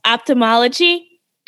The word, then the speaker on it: ophthalmology: You wrote in your blog about how you wanted to pursue, and I'm going to pronounce it wrong, ophthalmology. Did I get it? – Yeah, you got it.